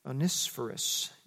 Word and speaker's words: Onesiphorus: Onesiphorus, you got to say that right.